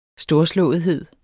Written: storslåethed

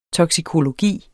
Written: TOKSIKOLOGI

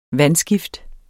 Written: vandskift

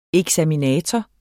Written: eksaminator

